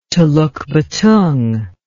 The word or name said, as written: telukbetung